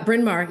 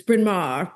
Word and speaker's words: Bryn Mawr: You can do the Katharine Hepburn version, which is Bryn Mawr. But yeah, Bryn Mawr is perfect.